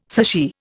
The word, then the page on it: sushi: Indian